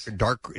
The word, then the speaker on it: Dacre: Dacre Montgomery. – Yes. – Dacre.